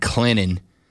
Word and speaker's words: Clinton: You're a good dude, but you just got yourself canceled because you pronounced the T in Clinton. All right. – I try my best. And I know the saying is the T is silent but sweet.